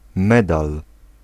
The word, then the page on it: medal: IPA: /ˈmɛdal/